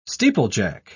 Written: steeplejack

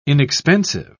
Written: inexpensive

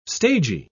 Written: stagy

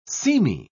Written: seamy

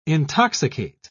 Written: intoxicate

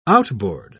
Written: outboard

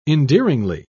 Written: endearingly

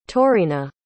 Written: taurina

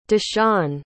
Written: dashawn

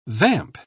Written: vamp

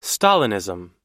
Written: stalinism

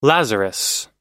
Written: lazarus

